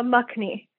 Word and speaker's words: makhni: Is it Makani Makni, right? – Uh, Makni paneer makhni is just cheese.